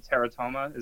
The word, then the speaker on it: Teratoma: I've been listening to Bugsy's EP, Teratoma. Is that how you pronounce it? – Yeah.